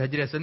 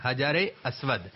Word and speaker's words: حجر اسود: اس کا صحیح تلفظ کیا ہے؟ حجر اسود. کیا ہے؟ حجر اسود. حجر اسود نہیں, حجر اسود.